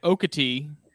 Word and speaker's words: Okatie: So there's a part, there's Okatee, which is spelled O-K-A-T-I-E, and that is considered like a county near, like right there with Jasper. And then there's the Okatee Hunt Club, which is spelled O-K-E-E-T-E-E. But I don't know anybody that actually pronounces it phonetically like that.